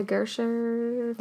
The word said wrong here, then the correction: Gasteyer: from Saturday Night Live. – Yes, Anna Gasteyer. – Gasteyer.